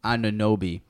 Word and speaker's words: Anunoby: OG Anunoby gets better. I probably butchered his name. Anunoby.